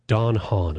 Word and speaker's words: Don Hahn: and she's going to be here along with Don Hahn. Am I pronouncing his? – You are. – Don Hahn.